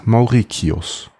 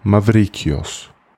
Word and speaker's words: Maurikios: Der eine hieß Germanos, der allerdings auf die Kaiserwürde verzichtet zu haben scheint, und der andere hieß Mauricius oder griechisch ausgesprochen Mavrikios.